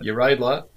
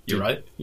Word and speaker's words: Uraidla: Uraidla, and that is how you say it, too. And I actually looked up how you pronounce it. – Right, okay. Is that... – Uraidla.